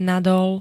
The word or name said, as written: nadol